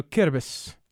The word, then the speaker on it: Kiribati: She rather butchered the name of the country Kiribati, or there's also Kiribis, if you want to say it that way as well.